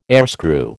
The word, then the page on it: airscrew: American English